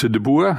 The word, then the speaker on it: de Boer: Ähm, also zuerst mal die Aussprache. Mein Name ist de Boer. Ich bin Niederländer,